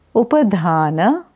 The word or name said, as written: Upadhana